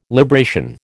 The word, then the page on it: libration: American English